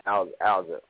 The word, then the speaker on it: Alza: So shout out to, am I pronouncing it right? Is it Alza Chameleon? – Yeah, Alza. Like, I had to help William how to say her name, too.